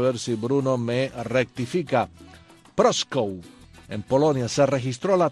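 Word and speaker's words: Prószków: Proskow, 1921, un día como hoy. Bruno me dice si lo pronuncié bien o no, que sabe polaco. Adelante, Bruno. – Proskow. – Ah, casi, casi me acerco.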